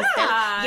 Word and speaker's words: pasteles: Pasteles? – Yeah. – Pasteles.